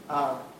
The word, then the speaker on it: Tanzania: I understand that, I want to say Tanzania, that's how we pronounce it, Tanzania.